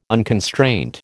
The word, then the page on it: unconstraint: American English